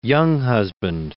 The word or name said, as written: Younghusband